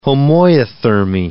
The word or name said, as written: homoiothermy